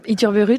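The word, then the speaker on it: Ithurburu: Isabelle Utzurburu, bonjour. – Bonjour. – Est-ce que j'ai bien prononcé? – Utzurburu, très bien.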